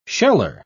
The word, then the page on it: sheller: British English